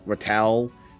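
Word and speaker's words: ratel: And technically, they're called, I could be pronouncing it wrong, it's R-A-T-E-L. – Rattel? – Rattel.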